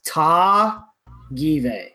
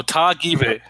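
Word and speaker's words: Tagive: How you pronounce Penny's last name is Ta Give, so it's like a hard first first syllable.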